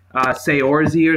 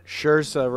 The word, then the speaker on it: Saoirse: Seorzy or something. Is that how you say it? Seorzy? – Saoirse Ronan.